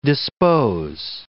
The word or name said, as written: dispose